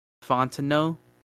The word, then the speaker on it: Fontenot: Yeah, and then the dudes that helped make some of those decisions, we got general manager Terry Fontenot. It's a silent T.